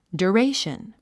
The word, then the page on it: duration: djuréiʃən; ドゥレィション